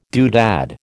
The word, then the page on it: doodad: American English